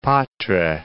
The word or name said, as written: Patrai